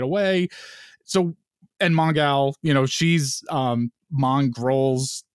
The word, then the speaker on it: Mongal: Yeah, yeah, um, I like that they pronounced her name with the gal at the end, and not just calling her Mongol.